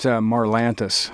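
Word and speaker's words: Marlantes: Now, how do you pronounce his name? – It's Marlantis. – Marlantis, okay.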